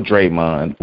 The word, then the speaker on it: Draymond: Just say no, Draymond, which rhymes with Raymond.